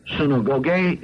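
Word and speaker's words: Synagoge (συναγωγή): Synagoge is the way it's pronounced in Greek.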